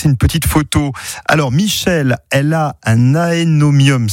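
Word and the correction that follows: Aeonium: Ça se prononce comme ça? C'est ça? – Aéonium. – Aéonium, très bien, qui semble végéter.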